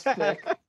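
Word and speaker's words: thicc: what's the word the kids use these days where they spell thick with two C's, you know? – It's still pronounced thick.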